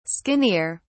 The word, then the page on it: skinnier: British English